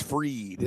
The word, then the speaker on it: Fried: All right, I'm gonna go with some fried chicken here as well, with Max Fried. It's not really fried, but it's spelled fried. – We like fried, but it's Fried.